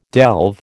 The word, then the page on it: delve: American English